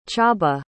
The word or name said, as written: Chaba